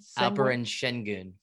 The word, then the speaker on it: Şengün: Is it Sanguine? – Alperen Şengün. – Şengün, thank you.